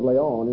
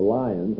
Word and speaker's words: Lyon: He was the second century bishop of Lyon in southern France, or Lyons, as it's sometimes pronounced in English, who became greatly concerned about these second century heresies, and his greatest work was Against Heresies.